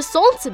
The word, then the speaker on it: солнце: Но вы же не пишете «солнце» без «л», хоть она также не произносится, верно? – На этом у нас пока все.